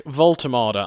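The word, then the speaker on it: Woltemade: Nick Voltermada, I believe, is how you say it. That is the German. You say each of the different syllables.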